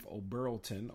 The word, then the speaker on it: Oberlton: O, o Oberlton, Atif Oberlton, O B E R L T O N, got his third win and his third knockout, three and all.